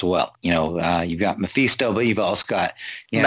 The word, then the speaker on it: Mephisto: Yeah, Mephisto.